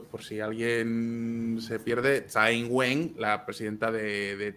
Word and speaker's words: Tsai Ing-wen: Sin tono. Eh, en, Tsai Ing-wen. Sí, es que la cosa es que tiene tonos, pero está bien.